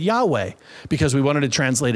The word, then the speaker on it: Yahweh: We look at that and go, we don't know how to pronounce that. So we go ahead and throw some vowels in there and we go ahead in English and pronounce it Yahweh, Yahweh.